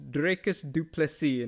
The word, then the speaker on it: Dricus du Plessis: Yeah, I remember he was I low-key asked him at a media day and he said it is Dricus du Plessis, and I'm like, yes sir, you know, bonjour.